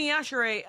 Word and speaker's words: Yashere: I think I pronounce it Jeanne and Yashiri. – Yashiri? – And I can't stop. – Well, it's Jeanne Yachere.